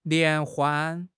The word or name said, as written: 联欢